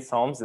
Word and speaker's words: Psalms: You know, you have that alliteration, even though the P in Psalms is silent there.